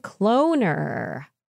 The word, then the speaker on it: Cloner: Cloner. As cloner rhymes with donor, from Stem Cell Technologies, made to increase the cloning efficiency of human pluripotent stem cells, HPSCs.